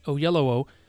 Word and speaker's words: Oyelowo: And so it's over though, and in an interview with David Oyelowo, let me say it right, Oyelowo,